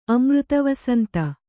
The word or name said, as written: ಅಮೃತವಸಂತ